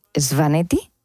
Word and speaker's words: Svaneti: Lo que más te impresionó del Gran Cáucaso, ¿no?, fue la región de Svaneti. – Así lo has pronunciado, bien, de maravilla.